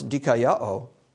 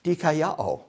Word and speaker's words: Dikaiao: And so the way that I remembered how to pronounce Dikaiao was that in my mind, I hear DK, that's a good thing, Yahoo, yay, DK Yahoo. There, now you'll always remember how to pronounce that word, Dikaiao.